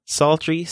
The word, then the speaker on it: psaltery: However, you know it's ancient because it has a silent P at the beginning. Just like the word psalm, psaltery starts with a P-S. When I was younger, I always thought it was fun to say psalms, but the P is silent, just like psaltery.